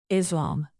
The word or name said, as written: islam